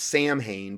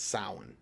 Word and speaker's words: Samhain: And it looks like it's pronounced Samhain, but it's actually pronounced Samhain.